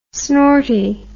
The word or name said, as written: snorty